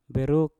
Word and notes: beruk: Mpur

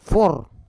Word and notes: for: Roon

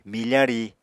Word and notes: mifa ri: Apatani

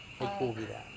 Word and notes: ikuvira: Serewen

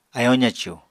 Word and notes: ayóo zacù: apatani

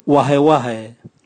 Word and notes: wahewahe: Raga

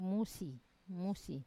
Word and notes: musi: Wamesa

